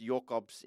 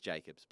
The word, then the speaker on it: Jacobs: I did ask him what he prefers, and he said, it's Jokobs in Afrikaans, but in English it's just Jacobs. But if you want to keep saying Jokobs, it sounds sick. So I'll keep going with it, but if you want to bail out and just say Jacobs...